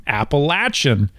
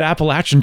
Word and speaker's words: Appalachian: have lived in that area for generations to say Appalachian. and to try and correct this huge error of pronunciation back to the original pronunciation. So when you said Appalachian Trail,